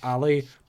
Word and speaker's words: Ally: I believe that's A-L-L-Y. Ali, like short for Allison.